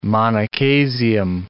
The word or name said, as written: monochasium